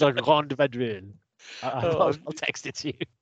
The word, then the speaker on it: La Grande Vadrouille: Our first film of Season 6, Episode 1, Weekend at Crombie's, will be La Grande Vadrouille. Sorry, La Grande Vadrouille. I've mispronounced.